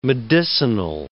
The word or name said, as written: medicinal